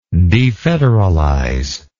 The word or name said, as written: defederalization